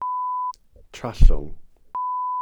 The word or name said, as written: trallwng